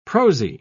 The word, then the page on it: prosy: British English